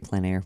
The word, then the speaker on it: plein air: And am I pronouncing that right, plein air, which is from our French friends, right? – Very good.